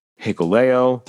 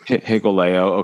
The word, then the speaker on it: Hikuleo: We've also seen El Fantasmo, Hikuleo. Did I say that right, Wade? – Yes, you did. – Hikuleo, okay.